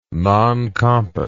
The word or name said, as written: non compos